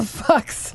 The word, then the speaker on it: Fuks: Pavel Fox. – How do you spell that? Interesting. – F-U-K-S.